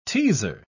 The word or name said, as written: teaser